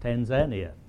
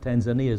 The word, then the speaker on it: Tanzania: That's how they taught me that it is pronounced, Tanzania. Not Tanzania as we pronounce it in our Western world.